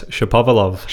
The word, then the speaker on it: Shapovalov: Shapiro, how do you say his name? – Denis Shapovalov.